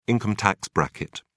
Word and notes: income tax bracket: British English

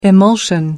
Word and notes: emulsion: American English

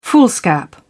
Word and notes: foolscap: British English

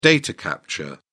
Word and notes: data capture: British English

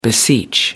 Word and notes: beseech: American English

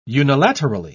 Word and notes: unilaterally: British English

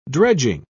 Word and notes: dredging: British English

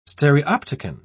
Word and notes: stereopticon: British English

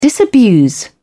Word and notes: disabuse: British English

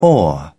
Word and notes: oar: British English

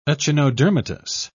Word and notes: echinodermatous: British English

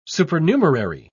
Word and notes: supernumerary: British English

